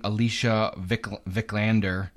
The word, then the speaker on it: Alicia Vikander: And we're watching Alicia Vikander.